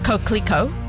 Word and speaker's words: Coquelicot: It is pronounced Coquelicot.